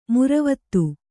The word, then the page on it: ಮುರವತ್ತು: muravattu